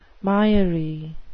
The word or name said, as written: miry